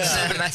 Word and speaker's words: Sèvres: El acentito, le erraste con el acento. Sèvres. – Sèvres. – Sí. – ¿Qué es Sèvres? – Hay un centro internacional de estudios pedagógicos en la localidad de Sèvres, que es en el conurbano de París, y la beca mía era para un ciclo lectivo, o sea, 9 meses.